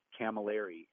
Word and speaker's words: Camilleri: And then also they can follow me on Instagram or Facebook at Anthony Camilleri. And my last name is spelled C-A-M-I-L-L-E-R-I.